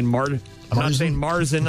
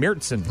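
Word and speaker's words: Märzen: We learned that from your daughter, Hannah, who taught me that I'm not saying Marzen, I'm saying Mirzen. – Right. – That's the proper German way to pronounce it.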